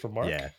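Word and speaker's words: Remarque: Eric, is it Remark? Just Remark? – Yeah, it is.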